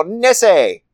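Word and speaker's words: Nessie: Otherwise known as Nesse. – That's not... No. – Yep. That's how they say it. – Do they? – Yeah, they go Nesse.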